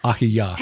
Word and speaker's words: Ahijah: Behold, well, and then Ahijah, but it's probably Ahiyah.